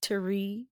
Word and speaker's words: Thierry: We've got Judge Reinhold as Thierry Martin. – Thierry. You got to say it in the accent, and then it comes out right. Thierry. – Spell that. – T-H-I-E-R-R-Y.